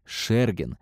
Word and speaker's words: Шергин: А если слыхали фамилию, то произносили ее неправильно. Правильно — Шергин.